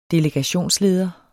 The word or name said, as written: delegationsleder